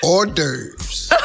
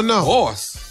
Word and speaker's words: hors d'oeuvres: H-O-R-S. H-O-R-S. – Huh? Wait. – Yeah. – No. – Horse. – The H is silent. Yeah, the H is silent. And so is the S.